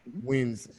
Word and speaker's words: Wiens: Your mother is the Canadian opera singer Edith Wiens. Am I saying that properly? Wiens? – Yes, absolutely. Wiens, yeah. – Wiens.